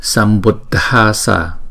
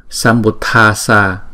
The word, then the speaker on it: sambuddhassa: Nếu mà chúng ta đọc là Sambuddhasa là không đúng, đúng không?